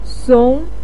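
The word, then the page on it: song: tone 2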